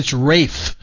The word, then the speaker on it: Ralph: I mean, they have Ralph Fiennes, Steve Coogan, Kelly McDonald. No, it's Rafe. – It's Rafe. – Rafe. – Yes, he does not pronounce it Ralph.